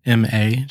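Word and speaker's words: Ma (間): I think he calls it Ma, M-A, just pronounce Ma.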